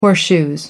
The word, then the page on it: horseshoes: British English